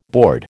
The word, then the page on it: board: American English